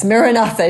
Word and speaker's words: Maranatha: So as we say it gently in four syllables. Maranatha. Maranatha.